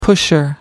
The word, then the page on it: pusher: American English